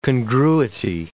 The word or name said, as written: congruity